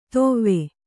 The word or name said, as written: ತೊವ್ವೆ